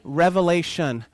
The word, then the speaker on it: Revelation: Revelation. Don't put an S on there, or somebody might tell you you're saying it wrong.